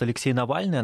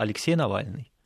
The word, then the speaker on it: Алексей Навальный: Я слышал, как она произносит Алексей Навальный. Она прямо вот по-русски это говорит. Не как англичане Алексей, а прямо вот Алексей Навальный.